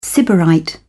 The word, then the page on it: sybarite: British English